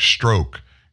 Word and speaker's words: Strzok: And Strzok is spelled S-T-R-Z-O-K. Rush Limbaugh, when he was alive, he would always say Peter Strzok stroke so he'd have the right pronunciation there.